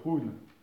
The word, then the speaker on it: Hun: Et au niveau du chêne, donc au niveau du psychisme, le psychisme du foie, on l'appelle le Run, qui s'écrit H-U-N.